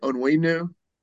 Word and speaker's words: Onwenu: Two, your Michigan guy, Mike Onwenu. How do you say it? Onwenu? – Yeah.